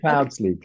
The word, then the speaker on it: Cloudesley: Am I pronouncing it correctly? Cloudsley. – Yeah, Cloudsley. – Okay. Cloudsley. Yeah. Sorry. It's one of those, like, I guess, English surnames that sometimes are like, oh God, I'm Italian, I have to pronounce all the letters.